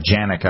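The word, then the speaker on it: Canik: The Janik I bought, which the C is actually pronounced as a J,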